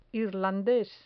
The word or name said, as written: irlandes